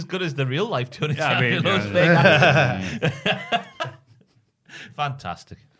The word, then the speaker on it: Tony D'Angelo: Tony D'Angelo. – D'Angelo. Sorry, pronouncing. – Tony D'Angelo. – Tony D'Angelo.